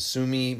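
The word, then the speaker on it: Tsumeb: And this fluorescent specimen comes from the, I believe the T is silent. It's T-S-U-M-E-B, the Sumi mine in Namibia.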